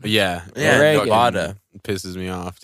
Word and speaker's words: Nevada: It's Nevada. That's hmm. just you said it correctly.